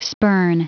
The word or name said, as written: spurn